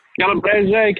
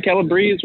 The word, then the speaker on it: Calabrese: Did I say that right? Calabrese, Calabrese, whenever you're feeling, if you want to Italian it up a little bit.